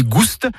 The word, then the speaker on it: gouste: Bourgogne Gouste, avec prononciation du S originel que l'on retrouve dans le provençal Gustus, goûteux, et qui a été remplacé depuis par l'accent circonflexe dans notre orthographe moderne.